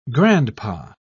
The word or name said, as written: grandpa